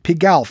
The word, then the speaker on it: Pigalle: Pigalle, French pronunciation.